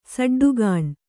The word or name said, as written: ಸಡ್ಡುಗಾಣ್